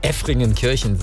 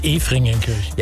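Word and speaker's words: Efringen-Kirchen: Also, was weiß ich, wenn man zum Beispiel Effringenkirchen sagt. – Statt Efringenkirchen.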